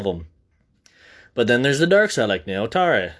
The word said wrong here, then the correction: Netorare: Netorare. I said it correctly this time. Netorare is Netorare, NTR.